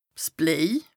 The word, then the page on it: 's Bléi: Bas Rhin